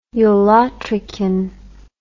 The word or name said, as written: ulotrichan